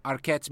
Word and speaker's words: Arquette: A Patricia Arquette's mediums.